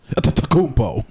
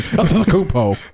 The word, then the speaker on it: Antetokounmpo: none other than Giannis Antetokounmpo. I think that's how you say his name. Antetokounmpo.